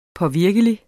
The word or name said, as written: påvirkelig